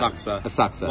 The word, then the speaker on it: Asakusa: Asakusa.